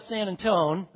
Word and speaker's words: San Antone: And you know you're a Texan when you go down to San Antone, that's how they say it, and you stand there in front of that mission, you got little tears coming out of your eyes?